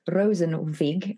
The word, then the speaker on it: Rosenzweig: So it's The Halo Effect by Philip Rosenzweig. I don't know if I said that right. You're more Germanic than me. Did I pronounce it correctly? – I would say yes, and I will put everything in the show notes that people can find it.